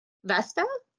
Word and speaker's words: Vesta: Vesta? – Vesta.